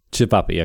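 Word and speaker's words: ćevapi: Cevapi.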